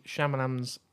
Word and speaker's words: Shyamalan: It's supposed to be Shyamalan's best film since Sixth Sense. – Definitely not how you pronounce his name. How do you pronounce his name? – Shyamalan. – Shyamalan. You said Shyamalan.